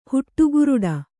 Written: ಹುಟ್ಟುಗುರುಡಿ

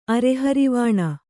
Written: ಅರೆಹರಿವಾಣ

